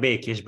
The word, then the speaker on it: Békés: It's Gabor Bekes, but that was close enough.